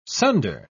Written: sunder

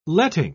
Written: letting

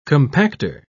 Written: compactor

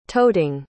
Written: toting

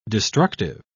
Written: destructive

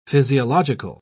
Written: physiological

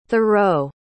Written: thoreau

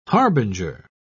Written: harbinger

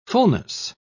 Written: fullness

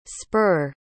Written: spurr

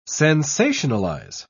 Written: sensationalize

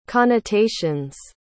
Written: connotations

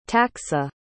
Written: taksa

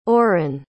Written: auren

